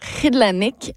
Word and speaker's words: Kilanik: Le titre, c'est ça s'écrit Kilanik, mais on le prononce Ridlanik en Inuktitut.